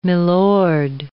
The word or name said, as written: milord